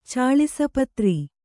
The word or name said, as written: ಚಾಳಿಸಪತ್ರಿ